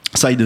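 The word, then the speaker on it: Syd Mead: Donc Sid Mead, hein, c'est comme ça qu'on ça se prononce. – Ouais. Side. – Ouais, autant pour moi.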